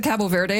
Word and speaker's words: Cabo Verde: And then this morning, you get the Cape Verde. You said Cabo Verde. I'm never sure how to say these things. – Cabo, yeah.